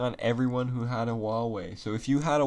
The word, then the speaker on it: Huawei: It's like H-E-I-U. – Oh, yeah, I know what you're talking about now. – Yeah, it's called... It's spelled really fucked up, but it's called... It's pronounced like Huawei.